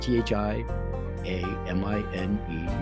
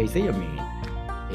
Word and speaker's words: thiamine: And if you have adequate thiamine or thiamine, you know, however you pronounce it, thiamine, T H I A M I N E, you say potato, I say potato, I say thiamine. you say theamine. It's still spelt the same way, however you phonetically prefer to pronounce it.